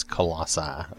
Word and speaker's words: Colossae: But I have gone with the idea that it's Colossae, okay? but it's not spelled with an I at the end. It's C-O-L-O-S-S-A-E, okay?